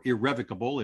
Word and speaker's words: irrevocable: Or into a trust, and you need to use an irrevocable trust here, or irrevocable if you're of that pronunciation preference.